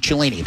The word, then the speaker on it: Cellini: F-L-I-F-F. Download the app. Use the promo code Cellini. That would be my last name, and that's spelled C-E-L-L-I-N-I to get started.